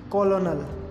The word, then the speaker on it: colonel: Lieutenant, L-I-E-U-T-E-N-A-N-T, lieutenant, which is correctly pronounced as lieutenant, lieutenant, lieutenant, lieutenant. Colonel, C-O-L-O-N-E-L, which is correctly pronounced as colonel. Chocolate, chocolate, C-H-O-C-O-L-A-T-E, chocolate, which is correctly pronounced as chocolate, chocolate. Desert, desert, D-E-S-S-E-R-T, desert, which we eat after eating food.